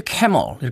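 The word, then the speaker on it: Camel: 사실 어떤 사람들이 캐멀 이렇게 발음하는데 저는 그냥 까멜이라고 중학교 때 처음 음악 들을 때 그렇게 발음했던 것이 아직도 어, 입에 붙어 있는 것 같습니다.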